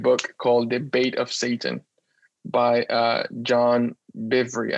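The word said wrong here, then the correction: Bevere: I think that's how you pronounce his name. – It's Bivier. John Bivier. – B-E-R-E.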